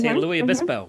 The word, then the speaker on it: San Luis Obispo: San Luis Obispo, mm-hmm.